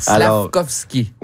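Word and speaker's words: Slafkovský: Slakovský. – Oui, ça s'écrit comme ça se prononce.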